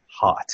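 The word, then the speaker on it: Haught: One of the characters' last names is Haughgt, right? – Which is pronounced hot.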